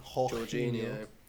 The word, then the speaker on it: Jorginho: But I've seen a lot about Jorginho this season. Jorginho. – That's just how my Italian friends have told me to pronounce it.